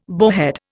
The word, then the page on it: bowhead: Indian